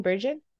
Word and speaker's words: Bergin: I should have asked you, is that how you say your last name, Bergin? – Bergin.